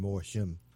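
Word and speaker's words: Mor Thiam: It spells his name M-O-R and last name T-H-I-A-M, but it's pronounced Morshim.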